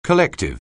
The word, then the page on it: collective: anglais